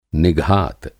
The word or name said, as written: निघात